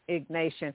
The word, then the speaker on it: Ignatian: So you say that your the foundation is the Ignatian spirituality. Am I pronouncing it right? – Ignatian. Yeah.